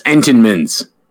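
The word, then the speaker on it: Entenmann's: Eatemans blueberry. – That's nasty. – Time out. Wait, pronounce that again, Jordan. – Eatemans. – That's Entenmans.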